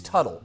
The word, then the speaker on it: Tuttle: Hunkahawk says Wisconsin coach Bo Ryan looks like is it uh, is it uh, is it Toidle? Is that how you pronounce it? We'll go Toidle. Yeah. Toidle uh, Orange County Choppers. I did see him at the Iowa Speedway once. It's Tuttle. Okay, thank you. It is Tuttle.